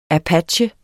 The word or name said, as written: apache